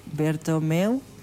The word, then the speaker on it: Bertomeu: Bertomeu, no sé cómo, si lo dije bien.